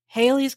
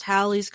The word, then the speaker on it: Halley: Haley's Comet is actually pronounced Halley's Comet because it has two L's. It's pronounced like Valley Halley.